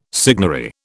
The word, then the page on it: signary: British English